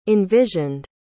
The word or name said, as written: envisioned